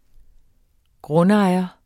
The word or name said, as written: Grundejerne